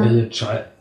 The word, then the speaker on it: Allegiant: Allegiant, wie also Giant, wie der Riese.